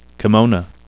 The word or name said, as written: kimono